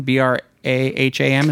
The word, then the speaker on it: Braham: Braham, is that how you would say that? How do you say that? – Excuse me, you sounded spot on.